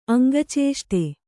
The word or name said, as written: ಅಂಗಚೇಷ್ಟೆ